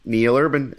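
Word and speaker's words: Neal Urban: Neil Urban, NEALURBAN.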